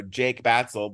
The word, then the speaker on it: Jake Batsell: And, He got to my name, you know, Jake Batsell, but he pronounced it Hak Batsell.